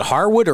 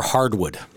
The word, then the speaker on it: Harwood: Is it Harwood or Hardwood? – It's Harwood. – Harwood. – No D.